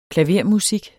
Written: klavermusik

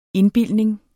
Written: indbildning

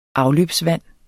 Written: afløbsvand

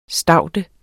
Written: staude

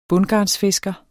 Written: bundgarnsfisker

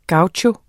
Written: gaucho

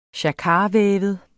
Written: jacquardvævet

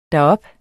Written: derop

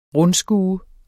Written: RUNDSKUETS

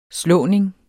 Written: slåning

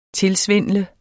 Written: tilsvindle

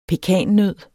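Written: PEKANNØD

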